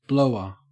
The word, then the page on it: blower: Australian English